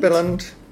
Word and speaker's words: Billund: Billund.